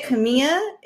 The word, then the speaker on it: Kimia: Is it Kimia? Did I say that right? – Yeah, I say it Kimia. It's the root word in like ancient Arabic and Greek of chemistry and alchemy.